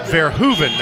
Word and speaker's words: Verhoeven: But we're hearing they pronounce it Verhoeven now. The PA announcer has been corrected, so we'll make that correction as well.